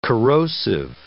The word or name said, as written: corrosive